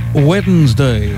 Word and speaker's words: Wednesday: Wednesday. – There you go. There are three syllables in Wednesday.